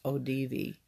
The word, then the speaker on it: Audivi: They are a professional vocal ensemble, and the name of their group, I think it's pronounced ODV. – I call it Audi 6, just so I can remember how to spell it. – I'm not it. – Sure, but it's spelled A-U-D-I-V-I.